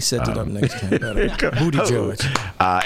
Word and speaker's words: Buttigieg: And how do you pronounce that guy's last name? – Buttigieg. – There you go. – Shake your Buttigieg.